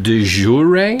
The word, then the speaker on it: de jure: You said de jure? – De jure, which I cannot pronounce very well. – No, you pronounce it well.